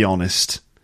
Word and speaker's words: honest: And hear the alveolar tap between never happened, never happened, to be honest. That has never happened, to be well, honest doesn't have one anyway, does it? Honest is a silent H, but uh, certainly has and happened.